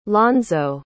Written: lonzo

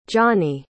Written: johney

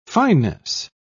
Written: finis

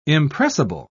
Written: impressible

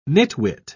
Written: nitwit

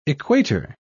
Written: equator

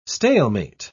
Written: stalemate

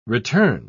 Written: return

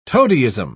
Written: toadyism

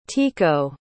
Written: tekoa

